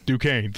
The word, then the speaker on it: Duquesne: 8:40 is DeQuesne. Did I say that right? – Duquesne, thank you, and Illinois, and then the game, and then tomorrow's games wrap up with Oregon and Creighton at 9:40.